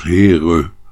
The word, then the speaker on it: Heer: Eh, het woord Heer alleen al ook, hè? uitgesproken meestal als Heere.